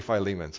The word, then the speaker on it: Philemon: Of the four epistles, there's Ephesians, Philippians, Colossians, and Philemon, or however you might pronounce Philemon. What's the other pronunciation? Philemon? Yeah, I say Philemon, so that's the right way.